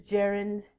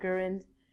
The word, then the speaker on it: gerund: The word is gerund, not gurund.